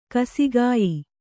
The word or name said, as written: ಗಾಸಿಗೆಯ್